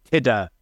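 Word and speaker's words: Tudur: And he repeatedly refers to him as Henry Tidor, not Henry Tudor. And that's quite interesting, because it shows that Richard was actually using the correct Welsh pronunciation.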